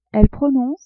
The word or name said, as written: elle prononce